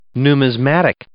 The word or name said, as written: numismatic